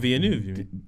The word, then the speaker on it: Denis Villeneuve: Apparently, another actor that's apparently everyone's looking, Delinuve, I can't really say his name, saying he needs to look Denis out. Denis Villeneuve, the Dune guy.